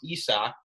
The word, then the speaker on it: Isaac: And Isak Paredes, which I still don't actually believe his name is pronounced Isak, but it is.